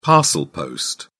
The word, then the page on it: parcel post: British English